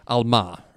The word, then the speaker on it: alma: And so in Hebrew, this word is, hold on, I've got it written down here and I want to make sure that I say it correctly, alma, right? So this word is alma in Hebrew,